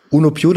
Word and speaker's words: Uno Più: Uno Ich, ich spreche es aus, bevor du es aussprechen musst, weil das kaum ein Deutscher aussprechen kann. Uno Più, das heißt äh, eins mehr.